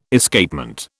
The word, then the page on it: Escapement: British English